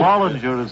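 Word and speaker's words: Follinger: Fowlinger is the name, F-O-L-L. – Well, now, on my rundown sheet, it's spelled F-A-R-L-I-N-G-E-R.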